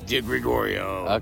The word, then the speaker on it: DiGregorio: So it's spelled like DiGiorno. – Well, no, it's not. It's not delivery. It's not delivery. It's not DiGiorno. – It's DiGregorio.